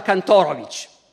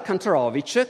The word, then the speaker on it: Kantorowicz: Cambia perfino la pronuncia del nome, perché in Germania pronunciava Kantorowicz, che per i tedeschi suona meglio. In America comincia a pronunciare Kantorowicz, che invece funziona meglio per gli americani.